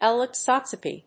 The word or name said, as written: ellipsometry